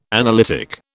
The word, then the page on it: analytic: British English